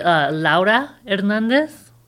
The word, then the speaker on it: Laura Hernandez: I pronounce it Laura Hernandez. It's the Spanish way.